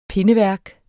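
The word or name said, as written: pindeværk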